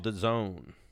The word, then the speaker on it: DAZN: It's called The Zone.